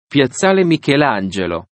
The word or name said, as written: Piazzale Michelangelo